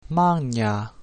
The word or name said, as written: magna